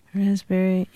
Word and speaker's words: raspberry: K-N-E-E-L, Neil. – Raspberry, answer. – Silent letters. – Yes, silent letters. Oh, yeah. Raspberry is... – The silent S... – P. – Or P. Sorry. – Silent P, right.